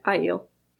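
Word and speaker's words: Aiel: Well, we have to wait till season 2 to see any ale other than Rand. – I eel. – I knew I was going to say it wrong, so I just kind of gave up. Say it again. – I eel. – I eel. That's confusing because it starts with an A.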